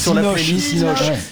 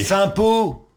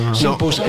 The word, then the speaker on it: Sympos: Donc voilà, il faudra aller regarder sur la playlist Synops. On est dimanche, il se fait tard, Synops. Tout le monde est fatigué. – Sympos.